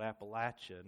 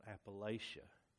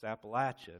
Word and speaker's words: Appalachia: And you're going up and down, and, you know, and by the way, that area of Kentucky, it's called Appalachia, not Appalachia.